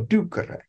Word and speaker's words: ducere: No long mark, so you accent the first syllable. Ducere.